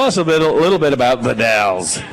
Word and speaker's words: The Dalles: It looks like Dallas or Dalles, but it's the Dalles.